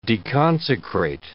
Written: deconsecrate

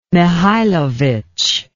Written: mihailovic

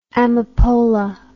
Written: amapola

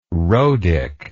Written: rhodic

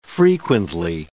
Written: frequently

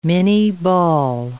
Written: minie ball

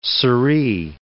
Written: sirree

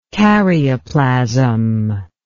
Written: karyoplasm

